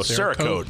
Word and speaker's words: Cerakote: Chiroque? Cherokee? – Oh, Cerakote.